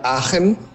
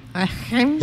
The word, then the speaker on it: Aachen: Se pronuncia Aachen. – Aachen. – Es como una, correcto, como una J, pero Aachen. – Pronunciada así como estirada, una J estirada.